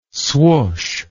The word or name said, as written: swoosh